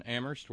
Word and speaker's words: Amherst: Thank you to Terry in Amherst, where only the H is silent.